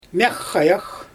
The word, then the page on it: mjAxxajax: Xyzyl